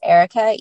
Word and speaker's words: Erika: But my Instagram is _ Erica Michelle. My name is spelled E-R-I-K-A Michelle. Or you can email me. My email is Erica, E-R-I-K-A .Driscoll.